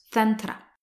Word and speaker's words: Tantra: qui en sanskrit se prononce Tantra.